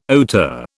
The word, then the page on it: hauteur: British English